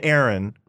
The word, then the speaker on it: Aaron: But they'll write E-R-I-N. Yeah. Even sometimes, if I say Aaron, mm-hmm. which is how I normally pronounce my first name, mm-hmm. they'll usually write A-A-R-O-N, but sometimes they'll write E-R-I-N.